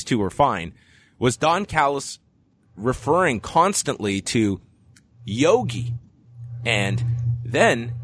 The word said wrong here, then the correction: Yagi: Kevin Kelly, not wanting to just completely correct his partner, starts calling him by the proper pronunciation of Yagi. And Callis goes, "What did you call him?" "His name, Yagi, not Yogi."